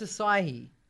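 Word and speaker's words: açaí: So it's acai. – Yeah. – Is how you say it.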